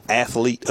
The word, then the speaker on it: athlete: That's athlete. That's two syllables. – Yes. – I want to tell the television stations – It's two syllables. Not athlete. – Not athlete. – Athlete. It's athlete. That's like nuclear.